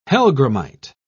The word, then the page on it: hellgrammite: British English